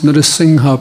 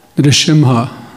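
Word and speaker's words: Narasimha: You have to pronounce Narsingha properly. – Narsingha musíme vyslovovat správně. – Many devotees pronounce Narsimha. – Mnoho daných říká Narsimha. – There's no Shimha in Sanskrit. – V sanskritu žádné Shimha není. – Lion means in Sanskrit Simha.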